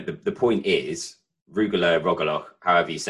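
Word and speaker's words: rugelach: But the first my first experience of Judaism was Rogaloch. Is that am I saying it right? Rogaloch? – The cake. – Yeah, the chocolate. Rugula.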